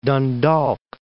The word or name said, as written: Dundalk